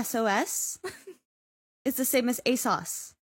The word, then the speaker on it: ASOS: Most of the clothes in Bat that you see are actually bought on, ASUS. I don't know if I'm saying that right. – ASOS? – ASOS? – ASUS. – It's an O. Not a U.